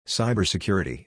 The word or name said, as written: Cybersecurity